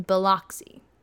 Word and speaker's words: Biloxi: She had a little sister named Cynthia, and Cynthia's daughter, Alice's niece, is still alive in Biloxi. Am I saying that right? I don't know. – Sounds right.